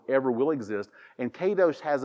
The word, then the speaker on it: kadosh: So our translation of the word holiness comes from this Hebrew word, and it's pronounced kadosh.